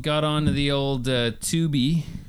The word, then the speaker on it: Tubi: No, Tubi.